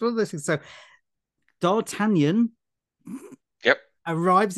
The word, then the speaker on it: D'Artagnan: I went to a very popular website and asked what the correct pronunciation was. – Yeah. – And it's D'Artagnan.